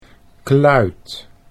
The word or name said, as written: kluit